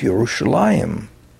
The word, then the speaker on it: Yerushalayim: Originally, people would have made an effort in the Greek-speaking world to pronounce the word the way the Jews pronounced it, which is Yerushalayim.